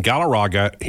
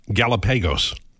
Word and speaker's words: Galarraga: And Galarraga, he mispronounces his name. He calls him Galapagos.